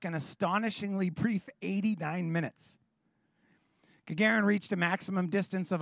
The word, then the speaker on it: Gagarin: On April 12th in 1961, a Soviet cosmonaut by the name of Yuri Gagarin, I'm sure I'm saying that wrong. Do you know Gagarin? Gagarin, thank you.